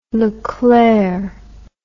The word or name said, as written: leclere